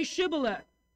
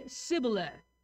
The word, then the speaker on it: Shibboleth: If he said, "No," then they would say to him, "Then say Shibboleth." And he would say Sibboleth, for he could not pronounce it right.